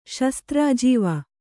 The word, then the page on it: ಶಸ್ತ್ರಾಜೀವ: kannada